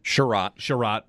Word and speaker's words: Chiarot: They also lose Ben Chiarot. Is that how to say his name? – Chiarot.